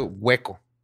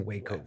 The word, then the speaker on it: Waco: Y se pronuncia hueco, como si estuviera hueco. – No, No, es W-A-C-O. – Pero le dicen Waco. Es Waco. Waco. Waco. Waco, Waco Texas. Waco, Texas, ok.